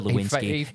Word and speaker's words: Lewinsky: Bill Clinton, former US president, had an affair with a young intern named Monica Lewinsky. It rhymes with Lipinski.